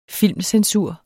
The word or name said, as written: filmcensur